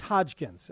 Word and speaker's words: Hodgkins: Like I believe the guy's name is Hodgkins, as the caller to Les said the other night. – I believe that is accurate. My apologies. I obviously will defer to a Les caller on the pronunciation.